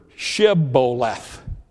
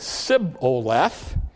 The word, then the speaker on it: Shibboleth: They said, if you're not of Ephraim, we want you to say the word Shibboleth. And when they tried to say it, they basically could not pronounce the H. And they said Sibboleth.